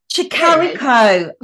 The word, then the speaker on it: Tricarico: Did I say that right? – Tricarico. – I've missed the C out of it.